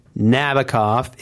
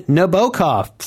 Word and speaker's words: Nabokov: It's not Nabokov. It's, according to the actual Nabokov, he preferred it being Nabokov.